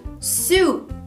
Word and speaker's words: soup: Em inglês, soup. Soup. Então lembre-se que tem um U nessa palavra para você lembrar de pronunciar soup. Soup. Diferente de sabão, soap. Soap.